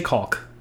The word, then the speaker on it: caulk: I pronounce the L in that. I say caulk.